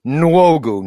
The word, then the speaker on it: Nwogu: Nwogu, like N and W. That's a tough one together.